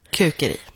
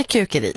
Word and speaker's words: Kukeri: För det är så det stavas, ja. – Med ett U. – Ja. Tycker jag vi säger, vi blir uppmanade här på Sveriges Radio att vi ska uttala det mesta svenskt om det går. – Precis. – Så vi säger Kukeri.